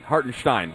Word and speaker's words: Hartenstein: I said, yeah, he's German. Hartenstein. That's how you say it.